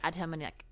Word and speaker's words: Atamanuik: The actor is Anthony. I'm going to so butcher this, just like I butchered that entire sentence. Anthony Adamanuik? – Oh. – Atamanuik? Anthony. – Oh, yeah. Okay. Anthony Atamanuik.